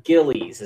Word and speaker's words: Gillies: Is it Gills, Gillies? John Gillies is how you say his name.